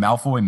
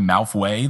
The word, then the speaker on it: Malfoy: Also, fun fact, I've been pronouncing Malfoy Malfway the whole time. I thought that's what it was. I don't know why. And then I was like, wait, it's spelled Malfoy.